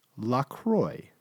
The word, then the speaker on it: LaCroix: It's pronounced LaCroix, like enjoy. So whenever you're stumbling over, think about that. I want to enjoy my LaCroix.